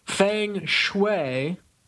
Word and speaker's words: feng shui: So yeah, that is feng shui is apparently the right way to say it.